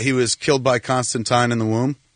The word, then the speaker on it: Constantine: Hey, that's what Alan Moore says it's supposed to be pronounced, Constantine, Valentine.